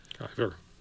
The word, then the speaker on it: Giæver: Nobel laureate Ivar Giæver. Am I pronouncing that right? – Giæver. It's Norwegian.